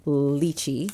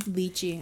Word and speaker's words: lychee: It sounds like it's most common for the States to say lychee. – Lychee. – And in the UK, it's more common to have lychee.